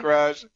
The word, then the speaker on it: Ra's: Speaking of nerd culture, like the way that people lose their bananas over just a pronunciation, because there's the camp that says it's pronounced Raish. – Raish. – Oh, yeah. – And then there's the Ra's.